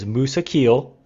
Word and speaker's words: Mousakheel: So, your last name is Mousakheel.